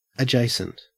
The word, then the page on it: adjacent: Australian English